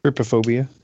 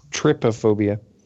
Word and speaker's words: Trypophobia: Yeah, how do we say number 3? – Trypophobia. – Herpophobia? – Trypophobia. Do I need to spell it for you? – Yeah. – T-R-Y-P-O-phobia.